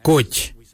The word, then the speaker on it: goodj: Karli is spelled G-I-I-L-Y-I, karli. For the traditional stone axe, we say koj, koj. Koj is spelled G-O-O-D-J, koj.